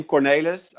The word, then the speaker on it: Cornelis: I hope you're going to do a kind of sort of shout out, but it's, it's my name, Cornelis. I think when I think about it, it's pretty difficult to pronounce in English. How it's like at CR Nailers.